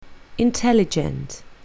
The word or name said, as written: intelligent